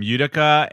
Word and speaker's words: Utica: What did you think of the winners of this week, which was am I gonna fuck up the name Utica, and did I say it right, Robbie? – That yeah, sounded okay, out.